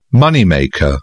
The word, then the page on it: moneymaker: British English